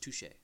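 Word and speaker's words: touché: Don't say touche and tush. – It's pronounced touche. – Thank you. – There's an accent aigu on the E.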